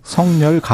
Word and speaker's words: 석열: 성열 가벽. 예. – 언어 정확한 어, 발음은 네. 석열입니다. 석열입니까? 이게 표준 발음이고요.